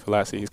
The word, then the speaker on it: Halasi: I mentioned that obviously you stepped on the field and played as a true freshman, but so did Vernon McKinley, Khalif Halos. – It's Verone McKinley and Khalif Halasi. They're kind of hard to pronounce.